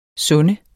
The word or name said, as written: sunde